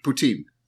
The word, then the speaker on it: poutine: Have you tried a dish called a poutine before? – A what? – Am I pronouncing it right? – Poutine.